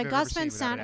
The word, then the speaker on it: Gus Van Sant: worst done by Gus Van Sant, if I'm saying that right.